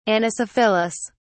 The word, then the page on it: anisophyllous: British English